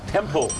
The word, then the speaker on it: temple: เขาบอกว่ายินดีต้อนรับเข้าสู่วัดนะครับท่านออกเสียงถูกแล้วครับ temple. ตัว L ครับ pronunciation ตัว L temple.